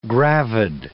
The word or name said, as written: gravid